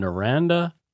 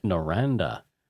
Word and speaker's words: Noranda: Moving forward, we have John Jutras, and John is from Noranda in Australia. – Yay. – Noranda. – Spell that. – N-O-R-A-N-D-A.